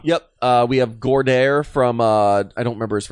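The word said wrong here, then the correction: Goedert: Gotta, is it Gordaire? Because I hear everybody else say Goddard. – It's Goddard. I'm just pronouncing it wrong.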